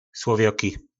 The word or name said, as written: slovjoki